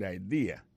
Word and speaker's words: idea: C'est I-D-E-A, ça veut dire idée. That's a good idea. Alors, accent vient de tomber dans la deuxième syllabe, là. One idea. Alors, moi, c'est idée. Vous faites trois syllabes, vraiment. I-D-A. On dit grand mot comme ça, il y a trois syllabes, là, dedans mes amis.